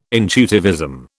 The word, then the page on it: intuitivism: British English